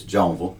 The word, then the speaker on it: Jonesville: Yeah, so as I was saying earlier, I am originally from the big city of Jonesville, South Carolina. I completely just said it incorrectly. It's Jonesville. The S is silent.